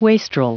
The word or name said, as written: wastrel